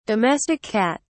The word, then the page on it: domestic cat: British English